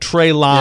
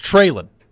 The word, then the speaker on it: Treylon: It's not Treylon. – Yeah, I don't know where this Treylon stuff has come from. – Well, it's how it's spelled. – It's Treylon. – It's Treylon.